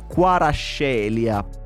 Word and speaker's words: Kvaratskhelia: I siti e le radio napoletane iniziano a istruire il pubblico sulla pronuncia corretta, Quarascelia.